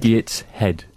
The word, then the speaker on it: Gateshead: Gateshead.